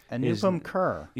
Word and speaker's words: Anupam Kher: Anupam Kher.